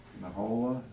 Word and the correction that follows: Nahalol: is that how you say it? Or close enough? – Nahalol.